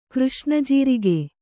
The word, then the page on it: ಕೃಷ್ಣಜೀರಿಗೆ: křṣṇa jīrige